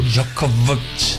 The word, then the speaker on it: Djokovic: Você viu aí a pronúncia? Quase um... – Eu vi. – Quase um... – Quase um nativo. – Djokovic.